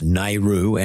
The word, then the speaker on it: NAIRU: There's this concept, it says it's pronounced Nairu, N-A-I-R-U, and it relates to the labour market and wages and productivity.